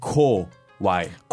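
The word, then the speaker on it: こわい: Not to be confused with kawaii. – Ah, it's the same. Their pronunciation is really close. – Really close. – Kawaii, kawaii. – Very close, very close. So we have kawaii, which is cute. And then we have kowai.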